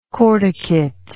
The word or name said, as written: cortication